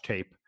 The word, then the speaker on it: Tape: Yeah, and first of all, the most important thing, his last name is spelled T-A-P-E, but you are correct. It is not pronounced tape like scotch tape. It is tape. And in fact, DBR poster Brevity came up with a great way for people to remember how to pronounce his name. He said, if an opponent tries to challenge our front court, they'll be hell to pay.